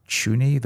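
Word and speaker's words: Çuni: Marvin, I think it's Chuny, the way that the little accent on the C from Rubin Kazan, and Liam Henderson is the big one.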